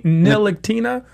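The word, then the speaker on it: Ntilikina: He definitely said Nicolina. Now, clearly, that's not his last name. His last name is Nilakina.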